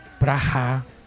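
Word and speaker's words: Praha: Y Hitler no bombardeó la ciudad de Praga, que entre otras cosas se pronuncia Praja, y que también en español sería Plaja, casi que plaga, ¿no?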